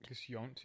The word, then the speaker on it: Yount: The man, Richard Yount. I don't want to say his name wrong because he's a solid dude. Richard Yount. Y-O-U-N-T. Yount? Yount. – Just Yount, yeah.